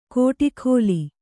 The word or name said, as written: ಕೋಠೀಖೋಲಿ